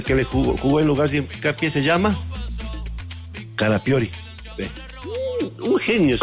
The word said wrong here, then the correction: Calafiori: Es Calafiori. – No, yo Calafiori.